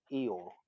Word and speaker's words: Io: there's the concept is Io, which is spelt I-O, and so that's the supreme creator, if you will.